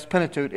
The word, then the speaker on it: Pentateuch: When I was in college, I took a course on Pentateuch, and I'm from the South, so it took me half the semester to figure out how to pronounce it correctly. But that's Pentateuch.